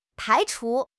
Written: 排除

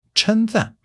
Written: cheng1 zan4

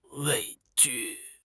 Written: 畏惧

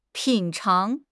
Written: 品尝

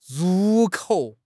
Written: ru4 kou3

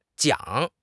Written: jiang3

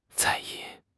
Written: 在意

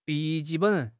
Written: bi3 ji4 ben3

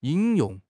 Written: 引用

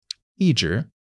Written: yi1 zhi2

